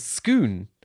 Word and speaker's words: Scone: like Bakewell and Scoon, which is spelt scone.